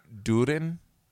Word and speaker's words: Durin: Durin. – That's how they pronounce it in The Hobbit.